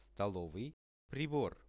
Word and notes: stolovyi pribor: Russian

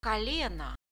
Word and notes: koleno: Russian

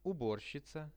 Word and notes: uborshica: Russian

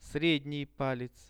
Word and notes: sredni palec: Russian

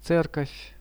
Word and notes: cerkov: Russian

